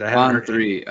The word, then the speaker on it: On3: On3, I think it is. – Oh, they're actually okay. I didn't know there was On3. I assumed it was supposed to be one.